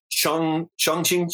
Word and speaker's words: Chongqing: I don't even know how to pronounce this, but I think it's Chongqing, China.